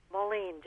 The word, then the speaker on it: Moline: Moline, just like the city.